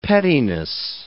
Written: pettiness

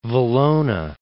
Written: Valona

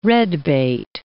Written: red-bait

